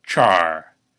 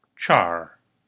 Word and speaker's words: char: It's char. That's spelled C-H-A-R. And yes, in Klingon, it's useful to think of C-H as a single letter. Say it after me now. Char. Again, char.